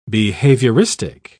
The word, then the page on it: behavioristic: British English